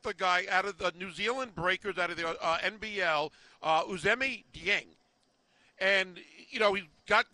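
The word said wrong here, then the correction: Ousmane Dieng: Usmane Dieng.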